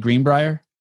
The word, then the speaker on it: Greenbrier: The Greenbrier, in 2000, did I say it right? Greenbrier? – Yeah.